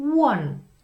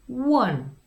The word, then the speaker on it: one: Pusu dáme na zvuk U, one, one, one.